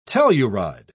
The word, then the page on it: telluride: British English